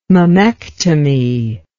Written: mammectomy

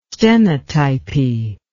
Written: stenotypy

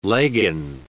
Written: legging

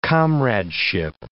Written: comradeship

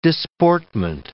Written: disportment

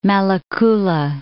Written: Malakula

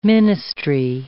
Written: ministry

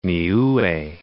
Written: Niue

